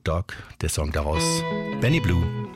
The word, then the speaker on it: Bleu: Wie die Liebe für Vicky schreibt sich auch der Nachname des nächsten Musikers, also B-L-E-U, ausgesprochen wird er aber als Blue, Benny Blue.